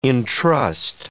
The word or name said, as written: entrust